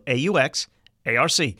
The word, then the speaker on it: Aux Arc: That's spelled AUX ARC.